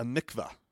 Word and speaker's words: mikvah: So anyways, a mikvah is what that's how you say it.